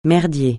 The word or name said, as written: merdier